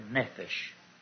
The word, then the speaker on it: Nephesh: Nephesh. Pronounced N-E-P-H-E-S-H. Nephesh.